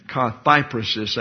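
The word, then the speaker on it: kathairesis: And kathypresis actually is the correct pronunciation.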